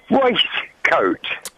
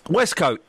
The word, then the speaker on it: waistcoat: It's spelt W-A-I-S-T, which pronounces waist. – Yeah. Lamb is spelt L-A-M-B, lamb. But we say lamb. The beauty the beauty of the English language is some words is not pronounced like what they are written. Westcoat is pronounced westcoat.